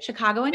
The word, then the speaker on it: Chicagoan: Is that how you say it? Chicagoan? – Yes. Yeah.